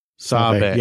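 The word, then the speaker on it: Sabé: So I was listening to your episode on Rogue Support, where you were a guest recently, and there was some conversation about Sabe. So actually, let's talk about pronunciation real quick. So it's Padme, so I'm assuming it's Sabe. – Sabe.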